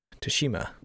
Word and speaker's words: Tsushima: But I hope I say it right. Ghost of Tsushima? – Tsushima. Ghost of Tsushima.